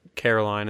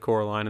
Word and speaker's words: Coraline: They're going to say Caroline instead of Coraline.